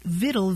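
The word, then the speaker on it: victual: And then Latin victualis passed into French, but along the way it lost that hard C sound, so it sounded more like vitae. And that word for nourishment without the hard C found its way into English. And eventually, people spelled it vittle, V-I-T-T-L-E, because that's sort of what it sounded like.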